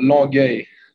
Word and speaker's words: Longueuil: It's called Longueuil.